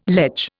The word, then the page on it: ledge: Indian